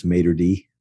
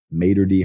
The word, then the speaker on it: Mater Dei: He's out of Mater Dei High School, a school that I badly mispronounced for years, Mater Dei. Mater Dei High School, not so fast.